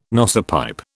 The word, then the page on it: nosepipe: British English